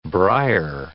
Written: briar

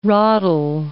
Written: ratel

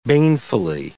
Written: banefully

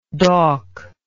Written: dauk

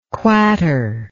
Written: quater

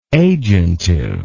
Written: agentive

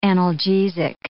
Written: analgesic

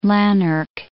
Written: Lanark

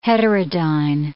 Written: heterodyne

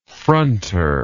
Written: fronter

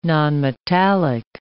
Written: nonmetallic

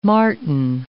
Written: marten

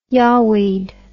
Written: yawweed

